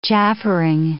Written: chaffering

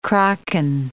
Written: kraken

